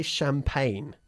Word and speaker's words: champagne: We say champagne. – Champagne. – It's our way of pronouncing or mispronouncing the French, okay?